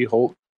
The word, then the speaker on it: Chulc: Swap the the whole C-H-U-L-C Hulk, isn't that it, or am I saying it wrong? – No, you're right, that's how you say it.